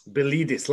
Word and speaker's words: Pilides: Pilides. – Like believe this, yeah? – Believe this, yeah.